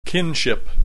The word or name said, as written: kinship